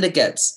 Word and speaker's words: Goetz: Goetz.